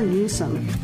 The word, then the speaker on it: Newsom: Governor Newsom.